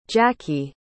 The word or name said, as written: jacky